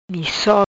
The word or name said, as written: Misso